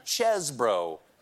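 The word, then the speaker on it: Chesebro: Now, to be fair, Cheesebro pronounces his name Chesbro.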